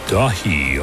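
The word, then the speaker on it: Daithi: album from Ilion called Dahi, although it's spelled D-A-I-T-H-I. I'm pretty sure it's in Gaelic.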